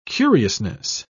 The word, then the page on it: curiousness: British English